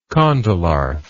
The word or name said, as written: condylarth